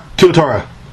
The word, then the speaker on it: tuatara: Tuatara.